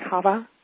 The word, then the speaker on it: Hava: Is it Hava? – Hava. I think if you want to be really technical, it's like Hava.